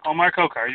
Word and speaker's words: Omar Khokhar: Omar Khokhar, you got it.